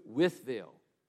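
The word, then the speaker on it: Wytheville: And so when you're riding up on 77 North through Virginia, you're going to try to make it to Withville. And that's how it's pronounced.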